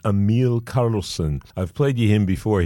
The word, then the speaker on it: Karlsen: His name is spelled E-M-I-L, first name, Emil, and then K-A-R-L-S-E-N, Emil Karlsson.